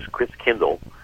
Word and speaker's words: Christkindl: And the German pronunciation of Christ child is Christkindl. And over the centuries, like Hinderkerk Kindergarten, Chris Kindle, over the centuries, it got pronounced Chris Kringle. So Chris Kringle is really Chris Kindle, which means Christ Child.